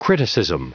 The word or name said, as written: criticism